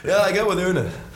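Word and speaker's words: Åne: They give a tip. The A is pronounced like the O in Una. – Horse.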